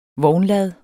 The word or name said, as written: vognlad